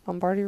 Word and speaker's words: Lombardi: They're not like in any particular order, he says, but the first one is The Lombardi Rules. Is that how you say it? – Yeah. – Lombardi Rules.